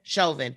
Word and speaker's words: Chauvin: Chauvin. – He was telling this Derek Chauvin guy, like, you have a good day. And then after the murder that occurred, when he was Derek Chauvin was leaving the scene, he said to him, you are a maggot.